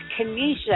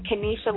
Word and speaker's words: Kenetia: My Twitter at symbol is Kenesha, and it's spelled a little different, K-E-N-E-T-I-A. My last name is Lee, L-E-E. So it's at Kenesha Lee.